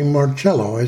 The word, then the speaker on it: Marchello: With my guest this morning, Holly Marchello.